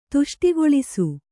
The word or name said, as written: ತುಷ್ಟಿಗೊಳಿಸು